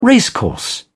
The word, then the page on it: racecourse: British English